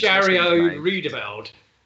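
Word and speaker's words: Jairo Riedewald: Jarrio Riedewald. – That's exactly how you say it.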